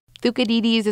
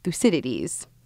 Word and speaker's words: Thucydides: Thucydides is more like the Greek pronunciation. You may have learned it as Thucydides, famous historian who thought to write about this plague in ancient Greece.